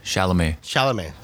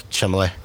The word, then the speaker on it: Chalamet: Chalamet. – Chalamet.